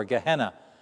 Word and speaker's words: Gehenna: or Gehenna, we pronounce it.